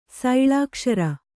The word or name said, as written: ಸೈಳಾಕ್ಷರ